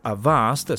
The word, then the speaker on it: Avast: Avast o Avast, secondo la pronuncia inglese corretta, che però in italiano non usa praticamente nessuno, è un nome molto noto nel campo della sicurezza informatica, in particolare per i suoi antivirus.